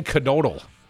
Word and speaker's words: Knodel: And your boy, Dan Knodel, and I'm pretty sure you pronounce the K.